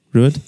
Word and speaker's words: Ruadh: So Ruadh, how would you pronounce that as a Scotsman? – Same word. – Ruud? Yeah. And then I wouldn't get too fancy with it. – And then Vor, pronounced exactly how it's spelled. – Yep. – M-H-O-R.